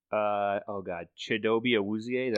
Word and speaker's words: Chidobe Awuzie: Chidobe Awuzie. Did I say that right? – Yep.